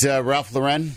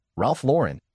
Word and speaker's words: Lauren: Is it Ralph Lauren? – Yep. – Yeah. – Then we get this email. It says, it's pronounced like the girl's name Lauren. It is not with an accent.